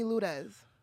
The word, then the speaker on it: Lourdes: No, my family calls me Ludez. – Ludez? – Oh, Ludez. – No, R? – That's how you say it. – R silent.